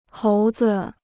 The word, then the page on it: 猴子: hóu zi